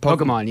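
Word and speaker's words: Pokémon: What do you think if you were to take your Pokemon, am I saying it right? – Pokemon yeah.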